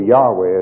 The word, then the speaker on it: Yahweh: Jehovah, or Yahweh as it should be pronounced,